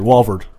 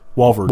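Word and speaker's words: Walvoord: Theological Seminary, Walvard. Walvard. – Walvard. – John Walvard. – I never knew how to say his name.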